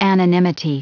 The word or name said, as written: anonymity